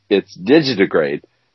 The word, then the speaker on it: digitigrade: This word is digitigrade is how you say it.